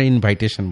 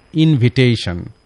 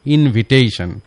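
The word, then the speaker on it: invitation: আরেকটি সেন্টেন্স বলি, রাকিব রিফিউজড মাই ইনভিটেশন, আমরা ইনভাইটেশন বলবো না, সঠিক উচ্চারণ হলো ইনভিটেশন, ইনভিটেশন, not ইনভাইটেশন। Rakib refused my ইনভিটেশন,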